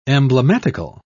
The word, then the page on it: emblematical: British English